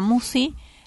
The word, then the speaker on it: Musci: El canal lleva mi nombre, Marisa Musi. La lista de reproducción es Serenamente. – ¿Cómo se escribe Musi? – M-U-S-C-I.